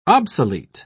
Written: obsolete

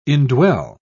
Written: indwell

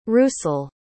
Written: rusul

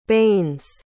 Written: banes

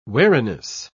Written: wariness

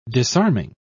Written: disarming

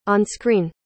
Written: on-screen

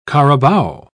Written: carabao

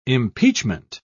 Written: impeachment

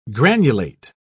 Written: granulate

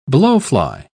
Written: blowfly

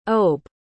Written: obe

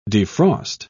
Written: defrost

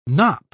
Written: knop

